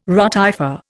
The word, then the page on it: rotifer: Indian